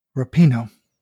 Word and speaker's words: Rapinoe: But Rapinoe, can we pronounce her name correctly? She did just win the World Cup and the Golden Ball and the Golden Boot. Can we at least pronounce her last name right? – Rapinoe. – Thank you.